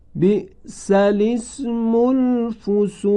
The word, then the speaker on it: بئس الاسم الفسوق: جوابش این هست که برای امکان تلفظ لام ساکن رو مکسور می کنن و عبارت رو چنین می خوانن: بئس الاسم الفسوق.